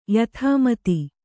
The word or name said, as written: ಯಥಾಮತಿ